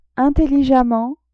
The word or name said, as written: intelligemment